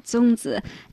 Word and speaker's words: zongzi: And I'm forgive me if I pronounce it wrong, but it's zongzi, right? – Well, you pronounced it wonderfully. – Oh, okay. – Yes, it is zongzi.